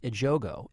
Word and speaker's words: Ejogo: I think her name is pronounced Ejogo.